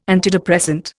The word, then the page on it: antidepressant: Indian